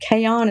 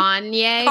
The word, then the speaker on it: Kanye: It's not Kayon. It's not Kayon. It's West. – Yeah, the West guy. How do you say his first name? – Kanye.